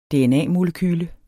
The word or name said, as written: dna-molekyle